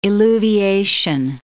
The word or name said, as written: illuviation